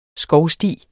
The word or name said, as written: skovsti